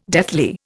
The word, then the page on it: deathly: Indian